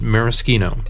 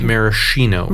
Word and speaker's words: maraschino: You actually pronounce it maraschino, not maraschino.